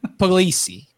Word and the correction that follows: Pugliese: Puglisi. – Pugliese. – Pugliese. – Oh God, E makes an A noise. It's just like Spanish.